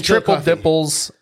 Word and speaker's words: Triple Dipple's: I said it's a very distinct name, Harreld Webster, at Triple Dippers. – Dipples. – Dipples. I didn't put an L. – It rhymes. Triple Dipples.